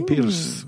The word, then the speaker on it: Pearce: Y el otro personaje, Felicia, su nombre masculino es Adam, y está interpretado por Guy Pearce. Pierce, lo he dicho bien. – Guy sí. Pierce, sí, Guy Pierce.